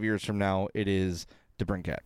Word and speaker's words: DeBrincat: He says, which young Blackhawk, DeBrincat, oh, I said it right, Hartman, Schmaltz. – you said it right.